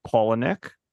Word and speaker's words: Palahniuk: Yeah, so like Robbie said, we're talking about David Fincher's Fight Club, based on a book which was written by Chuck Palahniuk, if you were curious about how to say his name.